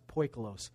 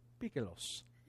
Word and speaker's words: poikilos: I used to pronounce it poikilos. But fortunately, we have good Greek friends that will help you out when you mispronounce a word. The closest I can get is poikilos.